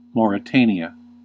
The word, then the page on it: Mauritania: /ˌmɒɹɪˈteɪniə/